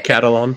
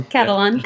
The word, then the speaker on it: Catalan: I think I'm pronouncing it right. – Yeah, Catalan. – Catalan.